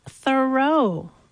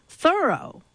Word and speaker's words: Thoreau: All this time, I thought his name was pronounced Thoreau, but no. – How is it pronounced then? – Thoreau.